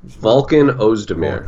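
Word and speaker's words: Volkan Özdemir: Cormier and how do you say his name? – Volkan Ozdemir. Volkan. Okay, Volkan Ozdemir from Switzerland, who just came on the scene and just has been knocking dudes out left and right.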